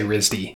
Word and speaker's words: RISD: Yeah, I would never think to pronounce it, to actually make it an acronym and say RISD.